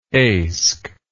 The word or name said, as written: eysk